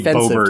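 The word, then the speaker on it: Boebert: The T because it's French. – Yeah, which means pronounce the T. – Yeah, so it's got to be Bobert.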